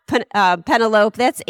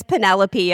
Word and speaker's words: Penelope: I laughed and then said, no, that's not Penelope. That's Penelope, and this is why.